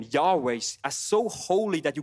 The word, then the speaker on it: Yahweh: But, um, you know, scholars, they, there's a consensus that says, like, probably these four letters were spelled like Yahweh.